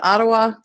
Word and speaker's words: Ottawa: Robyn lives in Ottawa.